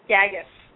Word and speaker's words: Gagas: Gagas.